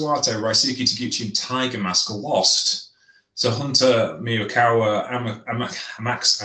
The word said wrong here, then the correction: Amakusa: I never pronounced his name properly. Amakusa, and Alejandro.